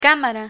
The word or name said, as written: camara